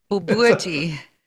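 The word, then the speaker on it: Bobotie: I think the risks, the Bobuti Bounty, right? – Bobuti. – I'm sorry? – Bobuti. – Bobuti, okay, Bounty.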